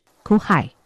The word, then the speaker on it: 去海: 泉州腔的发音呢，就是酷海。